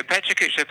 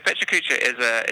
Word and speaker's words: Pecha Kucha: I saw in your bio that you're also involved with Pecha Kucha. Is that how you pronounce it? What is that? – Okay, Pecha Kucha. Pecha Kucha is a presentation format that originated in Japan about 15, 16 years ago.